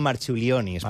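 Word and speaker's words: Marčiulionis: Jo crec que li diuen Marciulionis, però... Marciulionis. Perquè que aquesta C es pronuncia com una TX.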